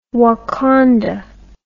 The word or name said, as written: wakonda